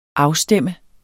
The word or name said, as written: afstemme